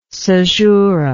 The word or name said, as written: cesural